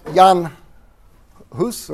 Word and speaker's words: Hus: Sometimes it's pronounced Jan Hus or Huss, or, you know, the different pronunciation. I'm just going to pronounce it John Huss. It seems more standard.